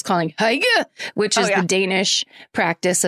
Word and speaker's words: hygge: So thanks, Trisha, except then Sarah Tunderman wrote in and said, quick correction corner, hygge is pronounced hygge.